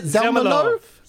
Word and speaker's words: Zelmerlöw: Zelmerlöw.